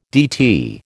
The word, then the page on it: DT: American English